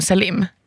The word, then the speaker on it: Selim: Är det inte Selim? – Jag vet inte, med tanke på att det är ett arabiskt namn. När jag googlar fram det så verkar det uttalas som Selim.